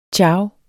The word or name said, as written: ciao